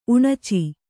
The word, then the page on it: ಉಣಚಿ: uṇaci